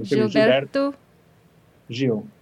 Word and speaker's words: Gilberto Gil: Am I saying it correctly? – Gilberto, but like the O sometimes sounds like a U. So Gilberto Gil. Gil. – Yes.